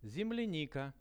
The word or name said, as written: zemlianika